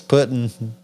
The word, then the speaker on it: Putin: Putang? What the fuck is it, you two dipshits? – It's Putin, bro. It's Putin.